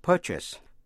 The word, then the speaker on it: purchase: Để ý đến cách phát âm của purchase, purchase nhấn mạnh vào vần đầu. A đọc như là a, purchase.